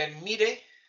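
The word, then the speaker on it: Elemmírë: El, el Enmire, con acento en la I, que es Mercurio.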